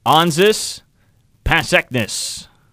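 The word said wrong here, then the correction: Anžejs Pasečņiks: Actually, it's Onsis Pasekness. – Oh, well. – Wrong emphasis on the wrong syllable.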